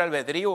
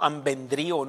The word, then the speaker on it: albedrío: Albedrío, no ambendrío. No sé cómo he oído gente que dice así. Albedrío, con acento en la I.